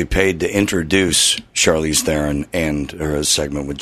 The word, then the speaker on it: Theron: It's Charlize Theron. Somebody give him the message so he can rant on me, because it drives me nuts. I must have called in 10 times about this. Not Theron, it's Theron.